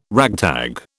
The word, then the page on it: ragtag: British English